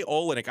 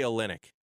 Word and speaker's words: Olynyk: Now, you say Olynyk. I say Olynyk. Who's right? – I think right? – Olynyk's right. I think I said it wrong.